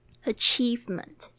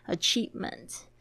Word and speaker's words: achievement: Achievement，注意一下，i e 的发音是 e 的长音。 Achievement，嗯，好的，那我们接下来讲这个 Nothing can be done 这样子的说法，就是说什么事都做不好，什么事都不能被做。